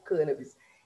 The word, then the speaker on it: cannabis: Bem estudado a respeito disso, e ele também adotava a pronúncia cannabis e aí, né, é, eu fui ver, inclusive com ele, e verifiquei que na Academia Brasileira de Letras é a forma como está registrada é como cannabis. Existem outras formas, é, é, é aceita também a outra forma, né, principalmente pelo dicionário Holsey, porém, a, como é cadastrado na Academia Brasileira de Letras, é como cannabis, e essa pronúncia adotada pelo professor Kalini, e essa também é a pronúncia a qual eu adoto.